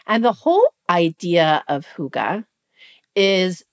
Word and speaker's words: hygge: What is sparking joy for me as we enter this fall season, smack dab in this fall season, is this concept of hygge. You've heard of this? – Yeah. – Okay. It's spelled H-Y-G-G-E.